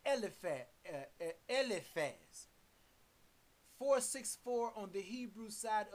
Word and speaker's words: Eliphaz: Let's get back to Job chapter 42, verse 7, where the Almighty Yahuwah is talking to Eliphaz, and let me hear that word pronounced in the Hebrew language. – Strong's age 464. Eliphaz. Eliphaz.